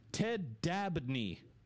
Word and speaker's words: Ted Dabney: Is Ted Danny just a guy who lives off... – Maybe I read the name wrong. – This is Dabney. It auto-corrected. – What's his name? – Ted Dabney.